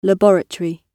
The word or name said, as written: laboratory